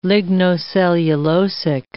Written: lignocellulosic

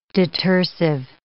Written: detersive

